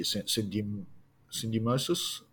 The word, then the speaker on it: syndesmosis: syndesmosis. Apologies for the terrible pronunciation, but no, I think you got it right that time.